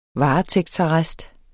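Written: varetægtsarrest

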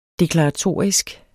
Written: deklaratorisk

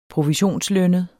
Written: provisionslønnet

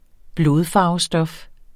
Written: blodfarvestof

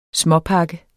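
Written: småpakke

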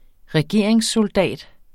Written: SOLDATS

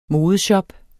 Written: modeshop

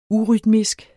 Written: urytmisk

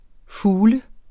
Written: fugl

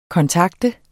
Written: kontakte

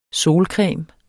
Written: solcreme

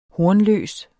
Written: hornløs

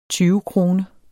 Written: tyvekrone

